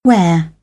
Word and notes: where: British English; eər

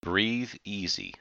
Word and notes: breathe easy: idiom

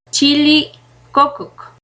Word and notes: chilli cococ: Náhuatl

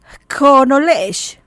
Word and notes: K koonele'ex: Maya

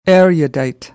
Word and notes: erudite: American English

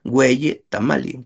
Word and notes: ueyi tamali: Náhuatl

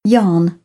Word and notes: yarn: British English; ɑː

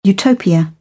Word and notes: utopia: British English

